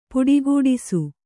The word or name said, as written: ಪುಡಿಗುಟ್ಟಿಸು